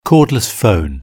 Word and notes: cordless phone: British English

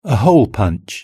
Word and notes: a hole punch: British English

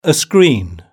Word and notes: a screen: British English

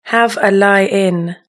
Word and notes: have a lie in: British English

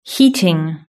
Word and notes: heating: British English

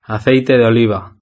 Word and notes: aceite de oliva: European Spanish

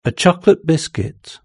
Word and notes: a chocolate biscuit: British English

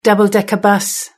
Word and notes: double decker bus: British English

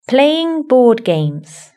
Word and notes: playing board games: British English

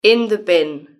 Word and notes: in the bin: British English

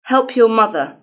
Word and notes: help your mother: British English

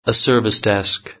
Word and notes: service desk: British English

